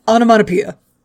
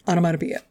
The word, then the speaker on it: onomatopoeia: The comic writers need to check their amanama pias, whatever, however you pronounce that word. – One more time? – No. Fuck you. – Onomatopoeia. – Thank you. – Onomatopoeia.